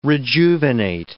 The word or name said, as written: rejuvenate